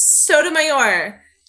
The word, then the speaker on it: Sotomayor: Her name is Sotomayor. Sotomayor. You can't even say her name.